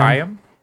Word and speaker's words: Higham: Is that how you pronounce it? I've never known. – Higham? Higham? – Higham. Yeah.